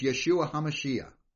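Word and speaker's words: Yeshua HaMashiach (ישוע המשיח): So in the Hebrew, Jesus' name would be pronounced Yeshua HaMashiach. Okay, that's the Hebrew, and you'll probably hear me say that from time to time, but that's what it is.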